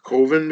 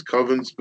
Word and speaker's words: Coven: but he pronounces it Coven because Coven sounds like oven.